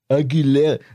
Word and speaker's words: Aguilera: Aguilera? Ja, siehste. Aguilera.